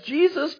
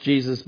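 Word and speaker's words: Jesús: And I remember looking at my baseball card going, man, wow, this guy's got the same name as Jesus Christ. You know, that's kind of cool until my mom, you know, informed me, well, it's pronounced differently. It's not pronounced Jesus, but Jesus.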